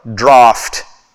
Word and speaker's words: draught: Now when he had left speaking, he said unto Simon, launch out into the deep and let down your nets, for a draught is how you would pronounce that.